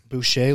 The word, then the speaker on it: Boucher: Boucher, like as in Bobby Boucher.